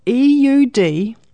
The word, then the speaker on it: eudaimonia: He called this eudaimonia, which I apologize for the pronunciation. So it's eudaimonia, if you wanted to look it up.